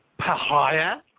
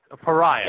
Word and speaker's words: Pariah: a band by the name of Pahaya. – Pahaya? – Am I pronouncing that correctly? – Not exactly. – Pariah. – It's close.